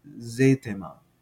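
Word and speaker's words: ζήτημα: And you'll see, by the way, that in the transliteration here, it's z-e-t. That's because eta is, and you see here that you got an E with a long, a macron over the top of it, which is indicative of a long E sound. So it actually sounds like an A, zete, zetema, right?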